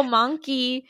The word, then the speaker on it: Monki: It's called Monkey. And I was cracking up when I was like, I'm like, oh, Monkey. Because it's spelled M-O-N-K-I.